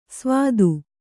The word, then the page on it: ಸ್ವಾದು: svādu